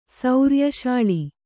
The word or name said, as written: ಸೌರ್ಯಶಾಳಿ